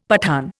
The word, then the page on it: Pathan: Indian